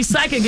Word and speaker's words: psychic: So you're right, because when we see psychic, it's spelled P-S-Y, right? – I couldn't tell you. – Yes, yeah. – So the P is silent.